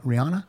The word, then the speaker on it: Rihanna: Rihanna.